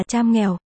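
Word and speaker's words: campur: Thuật ngữ này bắt nguồn từ một từ Mã Lai hay Indonesia Kampua, phát âm là cham nghèo, có nghĩa là trộn.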